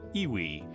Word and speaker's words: EWI: Electronic wind instrument, pronounced Iwi.